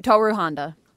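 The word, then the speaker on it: Tohru Honda: Yeah, Toru Honda.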